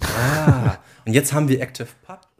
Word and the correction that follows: ActivityPub: und ActivityPub. – Ja. – Mensch. Jetzt, als ich als Nerds hat natürlich direkt falsch ausgesprochen.